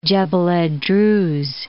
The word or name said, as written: Jebel ed Druz